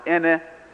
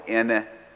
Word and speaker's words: ene: If you would downshift it yet more and pronounce it ene with low tone, it means the number four.